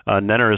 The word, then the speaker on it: Nenner: Nenner is spelled N-E-N-N-E-R.